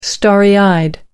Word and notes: starry-eyed: American English

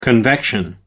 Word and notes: convection: American English

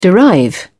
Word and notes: derive: British English

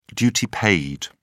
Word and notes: duty paid: British English

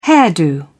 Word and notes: hairdo: British English

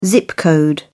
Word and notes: zip code: British English

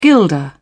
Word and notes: guilder: British English

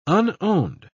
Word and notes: unowned: British English

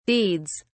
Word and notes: deeds: British English

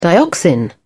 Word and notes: dioxin: British English

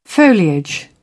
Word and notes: foliage: British English

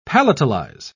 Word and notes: palatalize: British English

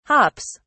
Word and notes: hops: British English

